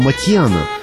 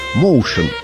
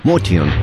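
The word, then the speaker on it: Motian: В первые годы 49 минут джаза я произносил его фамилию как Моушен. Потом великий знаток джаза, живущий нынче в Бостоне, Сабиржан Курмаев, написал мне, что Пол армянских кровей и что его фамилия и звучит по-армянски Матьян.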